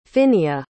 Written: Finnea

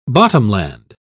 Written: bottomland

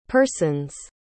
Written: person's

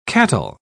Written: cattle

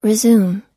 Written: resume

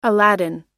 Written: aladdin

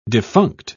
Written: defunct

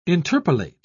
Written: interpolate

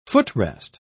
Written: footrest

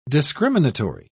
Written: discriminatory

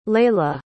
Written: Laleh

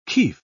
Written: kif

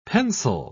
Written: pencel

